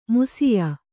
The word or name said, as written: ಮಾಷ್ಯ